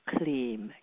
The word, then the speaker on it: Kleem: The fourth sound is Kleem, K-L-E-E-M. Again, phonetically, Kleem is the seed mantra of primordial pleasure.